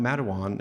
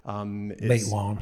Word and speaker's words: Matewan: Matewan and how that as a movie came about. – Matewan.